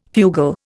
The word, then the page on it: fugal: Indian